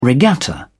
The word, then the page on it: regatta: British English